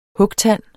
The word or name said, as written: hugtand